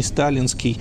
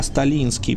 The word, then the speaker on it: Сталинский: Дело в том, что сам Сталин никогда не проявлял интереса к фигуре Евгения Стефановича, да и, откровенно сказать, фамилия его должна произноситься не сталинский, а сталинский, потому что у него были польские корни, он был сыном офицера-поляка, служившего на Кавказе.